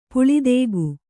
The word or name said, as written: ಪುಳಿದೇಗು